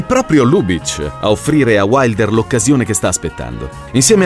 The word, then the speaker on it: Wilder: Sono anni difficili, ma il giovane Wilder, che nel frattempo ha americanizzato la pronuncia del suo cognome in Wilder, è fatto di una tempra fortissima.